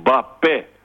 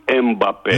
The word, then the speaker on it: Μπαπέ: Είναι εμ πρώτη συλλαβή, μπα δεύτερη και πε. Προφέρεται στα γαλλικά με έμφαση στο τελευταίο πε. – Ναι. – Πιο φυσικά δηλαδή στα ελληνικά είναι μπαπέ, όχι Εμπαπέ.